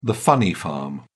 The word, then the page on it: funny farm: British English